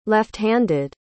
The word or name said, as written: lefthanded